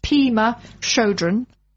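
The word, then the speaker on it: Pema Chödrön: Now, I may pronounce her name incorrectly. Pema Chodron. Is that how you would pronounce it, Daphne? – Decided, yes.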